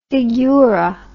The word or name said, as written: figura